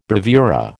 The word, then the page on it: bravura: American English